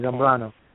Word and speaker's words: Zambrano: And my last name is Zambrano.